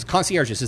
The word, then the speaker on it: concierges: Concierges, is that how you say it in the plural? It's not like silent S, right? Because it's French or something. – Neither the G nor the S is silent. It's concierges.